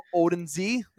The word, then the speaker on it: Odunze: Rome Odunze.